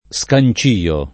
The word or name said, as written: scancio